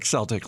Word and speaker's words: Celtic: is it pronounced Celtic or Celtic? He's like Celtic, like the Boston Celtics.